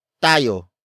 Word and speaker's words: tayo: The next word is tayo. Tayo. Tayo. Tayo is spelled as T-A-Y-O.